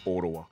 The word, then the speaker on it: Ottawa: I think it's Ottawa. – Ottawa? – Ottawa. – Ottawa? – That's how they say it. Ottawa.